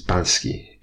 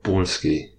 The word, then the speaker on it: Balski: In Polish, we don't change our vowels. We have one way of pronouncing every vowel, and A is pronounced A, as in hat, in the cat, in the sat, on a mat. His name is Balski. It's not Balski. He's not Polski-Bolski, he's Balski.